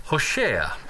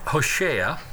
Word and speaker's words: Hosea: Open your Bibles to the book of Hosea, or as some of you might say, Hosea. In Hebrew, it's pronounced Hosea, even though it's spelled H-O-S-E-A.